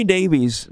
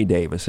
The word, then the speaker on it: Davies: By the way, Ray Davies, that's the way most people pronounce it. We had Ray himself on the show. Tell us, Ray Davis, in case anybody's going to complain.